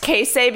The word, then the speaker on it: queso: It's pronounced queso because of the accent on the E.